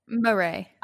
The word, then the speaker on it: Marae: Am I saying that right? May or Marie? – Marie. – I'm sorry, Marie.